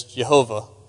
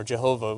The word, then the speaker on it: Jehovah: Uh, there's two very popular uh, pronunciations. One is Yahweh, you might have heard of that, and the other is Jehovah or Jehovah. We transliterate it with a J.